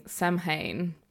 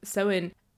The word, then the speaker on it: Samhain: I was one of those witches that kept saying Samhain because I was looking at how it was written and thinking that must be the right pronunciation. However, Samhain, which is how it's actually pronounced. It's a Celtic word, so it is pronounced different from how you see it written.